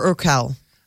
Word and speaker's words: Urquell: That I never know how to say it. Urkel, Urquell. – Pilsner, Urquell.